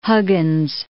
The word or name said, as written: Huggins